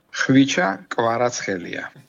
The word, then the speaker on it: Khvicha Kvaratskhelia: Deux syllabes qui claquent, faciles à retenir, car voici son nom complet prononcé par un journaliste géorgien. – Khvicha Kvaratskhelia.